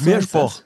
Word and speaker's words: Miesbach: Ja, also auch nicht Miesbach, sondern Mirschboch. Mirschboch. Ja? – Ah, okay, also mit R und SCH und O wird das ausgesprochen, ja? – Mirschboch.